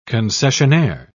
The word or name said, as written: concessionaire